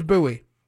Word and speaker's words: Bowie: I mean, my last name is Bowie. It's B-O-W-I-E.